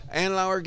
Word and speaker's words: Andlauer: Andlauer gets in, these people were already there.